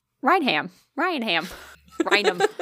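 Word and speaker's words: Raynham: It's going to be Rayum. – Rainum. It's fucking Rainum.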